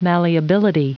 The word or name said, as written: malleability